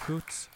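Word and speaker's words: Xóots: Hoots, by the way, means brown bear, and the raspy H sound at the beginning of the word is represented with an X.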